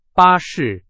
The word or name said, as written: 巴士